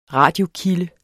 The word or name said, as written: radiokilde